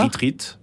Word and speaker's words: Ritrit: R-I-T-R-I-T. – D'accord. – Retreat. Comme ça se prononce.